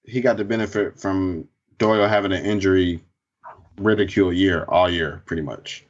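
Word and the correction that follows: riddled: Injury riddle.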